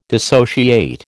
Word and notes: dissociate: American English

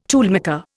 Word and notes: toolmaker: Indian

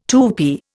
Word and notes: Tupi: Indian

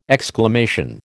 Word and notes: exclamation: American English

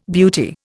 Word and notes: beauty: Indian

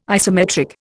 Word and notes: isometric: Indian

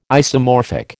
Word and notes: isomorphic: American English

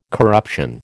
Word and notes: corruption: American English

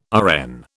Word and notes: RN: British English

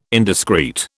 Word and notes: indiscreet: British English

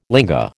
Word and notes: linga: American English

